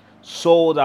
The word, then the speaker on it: Souled: And that is spelled with an S O U L E D intentionally so. No, I, I studied English, so I did not misspell it, I promise you. But it's sold out.